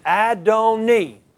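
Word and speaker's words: Adoni (אֲדֹנִי): This is Adoni. This is the way you pronounce it. Adoni.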